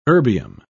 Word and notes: erbium: British English

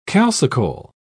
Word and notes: calcicole: British English

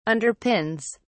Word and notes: underpins: British English

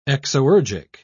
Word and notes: exoergic: British English

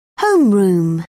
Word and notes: homeroom: British English

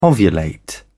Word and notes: ovulate: British English

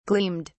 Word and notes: gleamed: British English

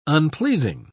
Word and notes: unpleasing: British English

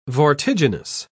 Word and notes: vortiginous: British English

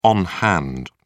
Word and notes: on hand: British English

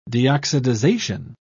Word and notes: deoxidization: British English

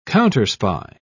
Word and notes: counterspy: British English

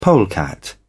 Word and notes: polecat: British English

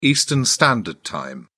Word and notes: Eastern Standard Time: British English

